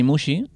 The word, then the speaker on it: Muxi: Mm, yo me llamo Dani Mushi, tengo un apellido extraño porque tiene faltas de ortografía, gracias a algún funcionario, entonces eh, se escribe Muxi con X, le falta una I por en medio.